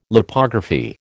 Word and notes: Lipography: American English

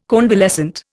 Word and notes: convalescent: Indian

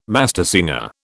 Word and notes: mastersinger: British English

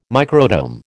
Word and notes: microtome: American English

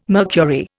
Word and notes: mercury: Indian

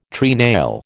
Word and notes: treenail: American English

